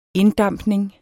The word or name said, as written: inddampning